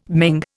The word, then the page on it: Ming: Indian